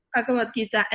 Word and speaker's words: Bhagavad Gita: Bhagavad Gita. – Yeah, that's how you say it. – Bhagavad Gita.